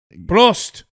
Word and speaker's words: Prost: Prost. – Prost. – Prost. P-R-O-S-T.